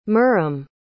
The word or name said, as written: mirrim